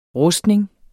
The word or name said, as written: RUSTNINGENS